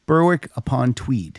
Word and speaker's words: Berwick upon Tweed: Berwick upon, just like once upon a time, and then Tweed. Berwick upon Tweed.